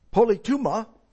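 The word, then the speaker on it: politema (πολίτευμα): Citizenship here is based on the Greek word politema is the correct pronunciation.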